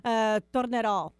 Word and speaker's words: Tornerò: Tornerò. – Tornerò.